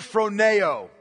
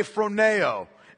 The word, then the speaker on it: phroneo: The main verb in this Greek sentence is the Greek word phroneo. Okay, phroneo. And I'll spell it for you because it's not spelled the way it sounds. It's P-H-R-O-N-E-O.